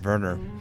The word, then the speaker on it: Werner: Plus, it's Werner. – That's right. – Rainer Werner Fassbender.